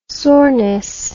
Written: soreness

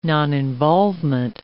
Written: noninvolvement

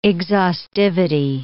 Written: exhaustivity

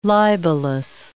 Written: libelous

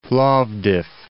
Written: Plovdiv

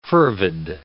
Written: fervid

